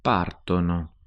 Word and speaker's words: partono: But with ire verbs, like partire, the they form ends in o, spelt with an o. O, ne, o. – Partono.